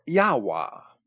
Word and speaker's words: Yahweh: Finally, there is evidence from more than one source that the modern Samaritan priests pronounce the name Yahweh or Yahweh. There is no reason to impugn the soundness of this substantially consentient testimony to the pronunciation Yahweh, or what looks like Yahweh. The encyclopedia author spells the first version Y-A-H-W-E-H and the second J-A-H-V-E-H, and I would pronounce them both the same.